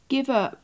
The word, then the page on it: give up: English